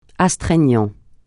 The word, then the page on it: astreignant: francais